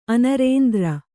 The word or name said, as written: ಅನರೇಂದ್ರ